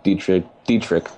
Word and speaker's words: Dietrich: Yeah, it was a 2-0 pitch, and he grooves a fastball right down the middle, and Dietrich, that's how you pronounce it, I think. He hits it out to right. – It's a hard CH. – It's a hard CH, yeah.